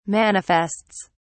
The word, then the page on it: manifests: British English